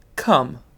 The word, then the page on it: cum: British English